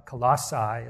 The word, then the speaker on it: Colossae: And this one man goes, and he lived in this town called Colossae, is how it's pronounced.